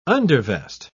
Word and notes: undervest: British English

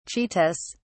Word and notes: cheetahs: British English

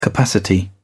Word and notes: capacity: British English

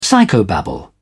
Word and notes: psychobabble: British English

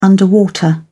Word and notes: underwater: British English

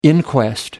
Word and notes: inquest: American English

